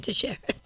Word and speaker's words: Teixeira: Teixeira. If it's Brazilian, it's Teixeira.